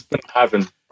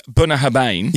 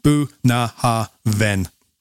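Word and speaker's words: Bunnahabhain: It has on the back phonetically pronounced Boona Harven, meaning mouth of the river in Gaelic.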